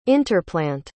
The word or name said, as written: interplant